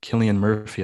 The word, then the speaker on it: Cillian Murphy: Starring Rachel McAdams and Cillian Murphy, I believe is how you pronounce his name. – Yep.